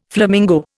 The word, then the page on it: flamingo: Indian